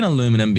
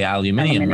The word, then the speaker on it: aluminium: And I'm saying aluminum because that's how it's spelt. Mm-hmm. But we would say aluminium, right? – Yep.